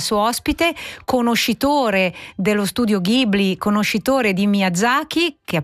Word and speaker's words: Ghibli: È una canzone molto piena d'amore, così come pieni d'amore sono i miei occhi quando io guardo i film dello studio Ghibli. Non so, o Ghibli come dicono i giapponesi, se volete proprio pronunciarlo alla giapponese, Ghibli loro lo leggono Ghibli.